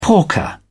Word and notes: porker: British English

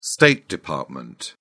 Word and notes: state department: British English

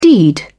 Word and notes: deed: British English

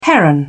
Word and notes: heron: British English

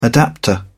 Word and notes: adapter: British English

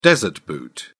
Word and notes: desert boot: British English

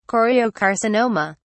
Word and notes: choriocarcinoma: British English

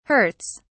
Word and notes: hurts: British English

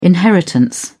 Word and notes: inheritance: British English

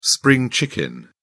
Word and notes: spring chicken: British English

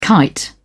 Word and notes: kite: British English

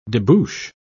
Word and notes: debouch: British English

